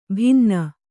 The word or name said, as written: ಭಿನ್ನ